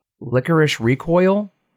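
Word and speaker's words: Lycoris Recoil: Nicole had me start watching, it's called, Licorice Recoil. That's how you pronounce it.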